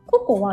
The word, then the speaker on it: KOKO: ココは COCO じゃなくて KOKO なんですよ。確かそれでココって読むんですよね。